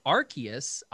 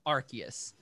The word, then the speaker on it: Arceus: And then the big one that came out was Pokemon Legends, and I guess now it is pronounced Arceus. I always thought really? – That's what they kept saying in the Pokemon Presents. They kept saying Arceus, like, in the whole thing.